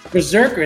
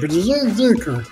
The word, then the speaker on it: BRZRKR: And Berserker. – It's spelled B-R-Z-R-K-R. So it's like Berserker. So there's no... There's no vowels in there.